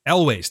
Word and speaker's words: Elwes: And wait, you're talking about Cary Elwes? – Elwes. Thank you.